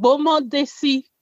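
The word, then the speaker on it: Gboma Dessi: It's called Gbomo Desi because like you pronounce the GB as Bo, so it's like Gbomo Desi.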